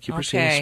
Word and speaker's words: Cupressina: So she wants to know what to do about a Cupressina spruce. – Yes. – Did I say that correctly? – Yeah, you did. Cupressina spruce.